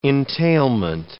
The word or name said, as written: entailment